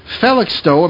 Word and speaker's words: Felixstowe: He is an electrician by trade and lives on the North Sea coast in Felixstowe.